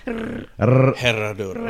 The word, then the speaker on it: Herradura: Can you roll your R's though? Herradura. – Okay. Herradura. – Herradura. – Yep. Herradura.